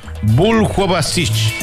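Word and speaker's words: Buljubasich: A ver, ¿cómo se pronuncia este? Buljuavisich. O Buljuavisich. Sí, se pronuncia Buljuavisich.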